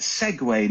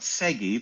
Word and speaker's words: Segway: Segway, not seggy.